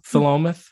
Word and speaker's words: Philomath: I'm going to say it wrong, Philomath? – No, you did great.